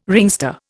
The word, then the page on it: ringster: Indian